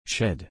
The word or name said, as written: shed